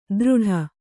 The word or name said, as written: ದೃಢ